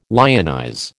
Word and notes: lionize: American English

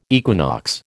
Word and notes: equinox: American English

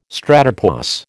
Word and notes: stratopause: American English

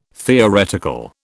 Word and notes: theoretical: British English

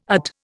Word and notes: UT: Indian

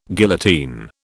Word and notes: guillotine: British English

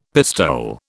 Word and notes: pistole: British English

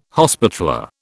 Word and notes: hospitaller: British English

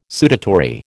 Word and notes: sudatory: American English

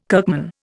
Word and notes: kirkman: Indian

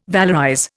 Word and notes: valorize: Indian

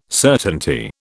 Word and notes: certainty: British English

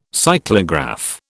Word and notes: cyclograph: British English

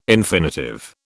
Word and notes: infinitive: British English

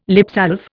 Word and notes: lipsalve: Indian

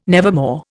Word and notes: nevermore: Indian

